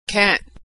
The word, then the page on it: can't: American English